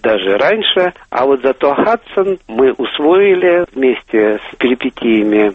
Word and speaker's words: Хадсон: Не «Гадсон» и не «Гудсон» или «Гудзен», а именно это закрепилось за Нью-Йоркской рекой «Хадсон». По-английски, по-американски произносится абсолютно так же, как имя домоправительницы Шерлока Холмса.